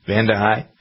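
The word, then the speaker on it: Vande Hei: Speaking of things that come out in the wash, NASA astronaut Mark Vandehei, is that how you pronounce the name? Vandehei, I think it is. Vandehei? – Sure.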